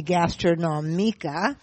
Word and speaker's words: Gastronomica: Attending, first of all, Gastronomica, which, if we're pronouncing it correctly, would be Gastronomica.